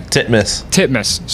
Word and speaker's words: Titmus: How do you say her name? – Titmus. – Sorry, yeah, Titmus. – T-I-T-M-U-S.